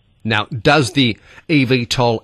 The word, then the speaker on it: eVTOL: Are they eVTOL? How do you pronounce the name of them? – It's eVTOL. – eVTOL.